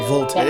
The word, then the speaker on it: Voltaire: Is that how you say it? Voltaire? – Voltaire. Yes.